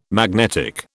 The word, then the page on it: magnetic: British English